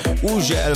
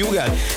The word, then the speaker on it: Hugel: Így van, az úriember, akinek minden országon másképp ejtik a nevét. Van, ahol Uzsel, van, ahol Hügel, van, ahol Ugel, meg mindenféleképp, de nálunk úgy mondjuk, hogy Hugel, és Szálltó és a Jamaican Bam Bam érkezik, a csavarjátok fel a hangerőt.